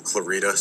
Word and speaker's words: Clarida: My first name is Christian, K-R-I-S-T-I-A-N. Last name, Clarida, C-L-A-R-I-D-A. – Clarida. I said Clarinda. Yes, I apologize for mispronouncing.